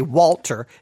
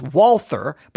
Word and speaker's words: Walther: I sometimes say Walter. It's Walther, but I always want to say Walter. It's Walther. So if I said it wrong in the earlier episodes, you know, shocker.